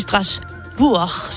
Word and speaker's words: Strasbourg: Du er DR's EU-korrespondent, og du står lige nu i Strasbourg, som er sådan, man udtaler det. – Mm. Det er du god til.